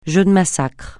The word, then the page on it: jeu de massacre: francais